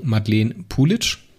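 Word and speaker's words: Madeleine Puljic: Jetzt habe ich mich belehren lassen, das wird ausgesprochen Madeleine Pulitsch.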